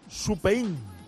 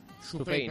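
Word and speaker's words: Supein (スペイン): Supein. – Supein, perfecto.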